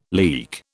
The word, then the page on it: leek: British English